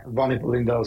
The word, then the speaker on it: von Hippel-Lindau: And you have been diagnosed with something called von Hippel-Lindau. Is that how you pronounce it? – Yeah, von Hippel-Lindau syndrome.